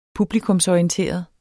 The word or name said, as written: publikumsorienteret